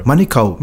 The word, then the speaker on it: Manukau: Manukau, Manukau. Eh? Isn't it? We're telling the boys, hey, it's not Manukau or it's not Mangri.